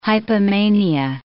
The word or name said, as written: hypomania